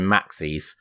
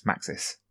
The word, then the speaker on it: Maxis: It's always been Maxis. Like Maxi. – Obviously, as in maximum. – To rhyme with Axis, Maxis.